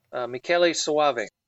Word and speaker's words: Michele Soavi: Is it Michele Soavi? How do you pronounce his last name? – Michele Soavi. – Okay, thank you.